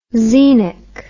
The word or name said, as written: xenic